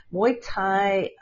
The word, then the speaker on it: Muay Thai: Is that correct? Am I pronouncing it right? Or is Muay Thai? Oh, wonderful. Muay Thai competitive fighter in Thailand and also in Australia.